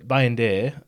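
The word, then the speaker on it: Bayındır: Because it means now that Bayindir, is that right? Mm-hmm. Right pronunciation?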